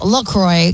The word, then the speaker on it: LaCroix: Officially, it's pronounced LaCroix because it rhymes with enjoy.